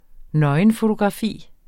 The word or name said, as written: nøgenfotografi